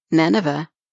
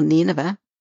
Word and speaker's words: Nineveh: God told Jonah that the people of Nineveh, or Nineveh, depending on how you pronounce it, did not know their right hand from their left.